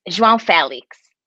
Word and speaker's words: João Félix: First is João. João, how do you say it? – João Félix. – João Félix. Okay, good. All right.